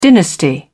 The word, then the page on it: dynasty: British English